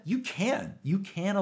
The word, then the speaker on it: EU-Can: You can Alliance here.